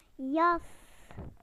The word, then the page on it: jacket: British English